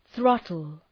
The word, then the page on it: throttle: ‘ɵrɒtəl